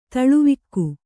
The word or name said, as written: ತಲೆವಾಗು